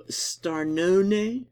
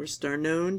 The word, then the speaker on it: Starnone: So, and the other book I'm thinking about reading is by Domenico Starnone, or Starnone, depending on how you want to pronounce it, yeah.